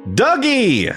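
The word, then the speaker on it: Dugee: Dougie.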